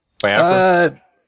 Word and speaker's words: Biafra: How do you pronounce his last name again? – Biafra? Biafra. – Biafra.